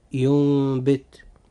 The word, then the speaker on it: ينبت: كلمة ينبت، هذه الكلمة جاء فيها حرف النون الساكن وتبعه حرف الباء، فهنا لابد أن يحدث إقلاب، فننطق هذه الكلمة هكذا: ينبت.